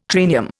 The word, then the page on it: triennium: Indian